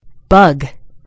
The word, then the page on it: bug: American English